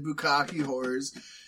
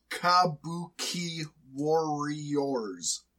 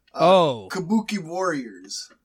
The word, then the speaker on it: Kabuki Warriors: So, some people are confused in the pronunciation of their tag team, and some have come and said Bukkake whores. Actually, if you break it down into syllables, it's actually Kabuki warriors. Kabuki warriors.